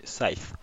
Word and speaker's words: Scythe: Oui, parce qu'il y en a qui disent Scythe aussi, enfin il y a un peu toutes les prononciations, mais je crois que la vraie prononciation c'est Scythe.